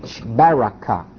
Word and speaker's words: Berechah: Now, in another place, in 2 Chronicles 20, 21 to 26, 2 Chronicles 20, 21 to 26, it's called the Valley of Beraca, B-E-R-E-C-H-A-H, Beraca. Beraca, however you want to, wherever you want to put the syllable.